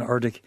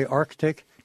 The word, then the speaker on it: Arctic: My guest tonight is John Lellenberg, who's co-editor of Dangerous Work, Diary of an Arctic Adventurer. Do you require yourself to say Arctic? Do you give the middle C pronunciation? – Always.